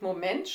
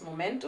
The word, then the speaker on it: Moment: Naja, Moment und Moment schreibt man zwar gleich, aber die Aussprache ist etwas anders. Moment und Moment.